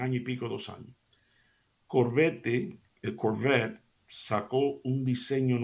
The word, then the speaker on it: Corvette: Eh, nosotros, en inglés se dice Corvette, pero en español nosotros decimos Corvette, porque así es como se escribe, ¿no? C-O-R-V-E-T-T-E, -E, Corvette.